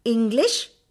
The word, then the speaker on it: English: There are other words which begin with the letter E, but they are pronounced like words that begin with the letter I. English, England, economics, eternal. Now we will look at some commonly mispronounced words. W-H-E-R-E, wear, everyday. W-E-A-R, to put on alengil tharikyuga, wear.